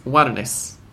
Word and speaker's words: Warnes: Their results have been 1-1 against Atlético Mineiro, 2-1 win away to Libertad, and 2-0 win at home to Sport Boys Warnes. Is that a good Spanish pronunciation? – Yeah. – The aspirated S is one of my favourite letters to say in Spanish.